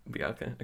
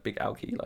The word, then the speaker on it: Bigalke: Bigalke. Okay, yeah, the way I was going to say it was like Big Alky, like he was sort of had a drinking problem.